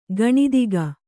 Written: ಗಣಿದಿಗ

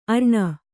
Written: ಅರಣ

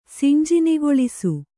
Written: ಸಿಂಜಿನಿಗೊಳಿಸು

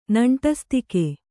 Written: ನಂಟಸ್ತಿಕೆ

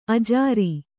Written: ಅಜುರ

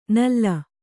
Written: ನುಲಿ

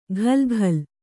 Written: ಘಲ್‍ಘಲ್

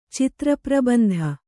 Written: ಚಿತ್ರಪ್ರಬಂಧ

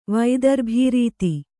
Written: ವೈದರ್ಭೀರೀತಿ

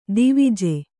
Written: ಧ್ವಜ

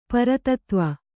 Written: ಪುರಾತತ್ವ

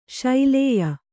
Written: ಶಲ್ಯೆ

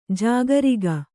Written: ಜಾಗರಿಗ

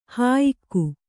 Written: ಹೊಯಿಗೆ

